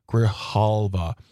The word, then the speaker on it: Grijalva: It's a Democrat, a democratically elected woman named Adelita Grijalva. Sorry, I pronounced that incorrectly. Grijalva.